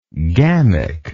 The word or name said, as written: gamic